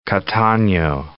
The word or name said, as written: Catano